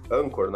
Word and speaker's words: Anchor: E o, o interessante dessa, dessa plataforma ali que o, que o Sérgio mostrou agora, ali, o, o Anchor, o Anchor, cada um chama de um jeito a pronúncia ali, né? Mas seria Anchor, né?